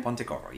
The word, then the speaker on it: Pontecorvo: I would argue that, like, okay, first of all, a quote from Pontecorvo. Am I pronouncing that correctly? – Pontecorvo, yes.